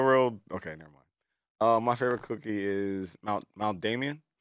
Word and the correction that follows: macadamia: Oh, macadamia.